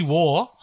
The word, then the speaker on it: Waugh: You decide on a B. Stevie Waugh. – Yeah, that is correct. That's how you pronounce it. That's how you pronounce Stevie Waugh.